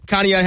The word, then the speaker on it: Conneaut: Over Trinity, and then at halftime, Conneaut has a 14-12 lead over Berkshire.